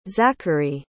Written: zachery

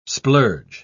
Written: splurge

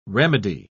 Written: remedy

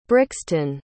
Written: brixton

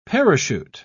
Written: parachute

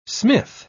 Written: smith

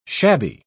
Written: shabby

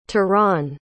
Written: Tiran

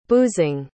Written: boozing

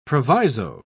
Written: proviso

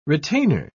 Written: retainer

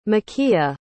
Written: makeiah

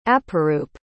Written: aparoop

